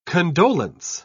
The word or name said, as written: condolence